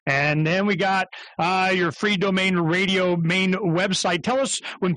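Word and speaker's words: Freedomain: You know, how do you say that? I call it free domain radio. – That's perfect. That's just great, because it's freedom is the main thing, and you want to be free in your own domain.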